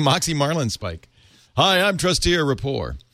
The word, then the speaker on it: Trusteer Rapport: wonders about, I don't know what this is, maybe you do, trusteer rapport. Am I saying that right? – Yes.